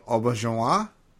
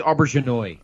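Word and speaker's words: Auberjonois: Rene Auberginois, I guess is how you would pronounce it. – I think it's Auberginois. – Auberginois.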